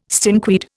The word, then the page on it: stinkweed: Indian